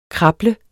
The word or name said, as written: krable